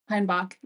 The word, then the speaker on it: Heinbach: But anyway, so Heinbach is your last name, or Heimbach? – Heinbach, yep.